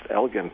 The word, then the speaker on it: Elgin: We have the Elgin, it's pronounced Elgin platform, in the North Sea, off of Aberdeen, in between Scotland and Norway, that had a blowout.